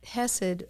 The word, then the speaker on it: chesed: wonder if that word loving kindness is hesed. – I'll look it up. – Okay, Kali's going to look it up. It's hesed. In English, you would spell it C-H-E-S-E-D. In Hebrew, it's chet, samek, dalet.